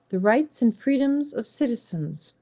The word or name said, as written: Rights and freedoms of citizens